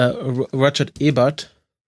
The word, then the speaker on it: Ebert: E, äh, Roger Ebert, so spricht man dann aus.